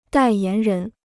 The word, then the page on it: 代言人: dài yán rén